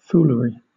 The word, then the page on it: foolery: British English